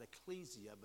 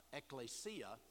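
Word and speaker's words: ekklesia (ἐκκλησία): Now, most people today will pronounce that ecclesia, but that's a mispronunciation. That's being informed by the English rather than going back to the Greek. The Greek is ekklesia, and it's found in the New Testament.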